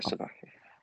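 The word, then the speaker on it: Tai Sabaki: It was Ty Sabaki. Is that right? Have I said that right? – Yeah. – Ty Sabaki was basically... – Yeah, Ty Sabaki.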